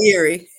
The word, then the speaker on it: Sayuri: And we're here with the lovely Miss, hopefully I don't say your name wrong, Miss Sarai. Please tell me. You gotta unmute yourself. – Sairi.